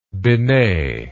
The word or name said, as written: binet